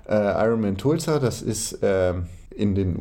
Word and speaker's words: Tulsa: für Tulsa trainiert, überlegt, oh Gott, wir müssen doch nochmal klären, wie es ausgesprochen wird. – Tulsa.